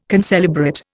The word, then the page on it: concelebrate: Indian